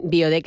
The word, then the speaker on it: Byoode: Biode, que se escribe B-Y-O-O-D-E, que es como suena fonéticamente beauty sí. – en inglés, ¿no? – Sí.